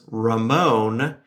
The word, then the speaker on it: Ramon: To capture Ramon's personality properly, you must roll the R mm. when hmm. pronouncing it if you want to go all the way. Throw your hands up at the end like a bullfighter or a salsa dancer would do.